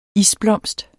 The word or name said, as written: isblomst